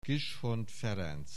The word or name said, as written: Kishon Ferenc